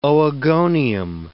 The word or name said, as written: oogonium